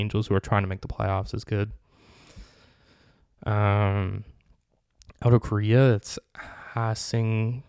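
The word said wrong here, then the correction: Ha-seong: I feel bad for pronouncing this name wrong. Ha-Sung Kim, a 25-year-old shortstop, 30 home runs, 24 doubles, and 137 games.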